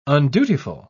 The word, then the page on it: undutiful: British English